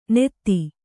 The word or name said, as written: ನೆತ್ತಿ